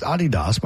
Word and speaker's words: Adidas: It's pronounced Adidas by the German manufacturer.